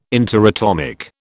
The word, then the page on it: interatomic: British English